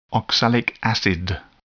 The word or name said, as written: oxalic acid